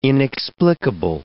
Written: inexplicable

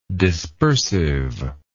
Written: dispersiveness

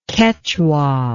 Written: kechua

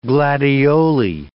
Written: gladioli

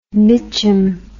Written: mitchum

